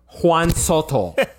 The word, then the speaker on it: Juan Soto: You said his name wrong. – Juan Soto.